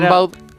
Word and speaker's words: Rimbaud: Rimbaud.